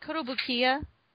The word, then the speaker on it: Kotobukiya: And then on the RPF side, I should say the Din and Helmet side, we got the Weta Workshop, Amalgamated Dynamics, Kotobukiya? Did I say that right? – I think Kotobukiya.